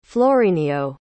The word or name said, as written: florinio